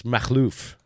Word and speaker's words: Makhlouf: I say it's Rami Makhlouf, but that's not actually technically correct. – No. I mean, like a lot of immigrants, how do you say it? – It's Makhlouf.